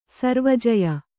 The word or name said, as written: ಸರ್ವಜಯ